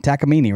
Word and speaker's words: Takamine: How do you say that brand? Takamine, right? – Takamine. – Yeah, that's how I've always said it.